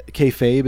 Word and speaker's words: kayfabe: Yeah, kayfabe, is that how you pronounce it? Yeah. – Yeah.